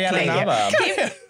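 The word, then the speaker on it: Carrie Ann Inaba: Carrie Ann and Abba.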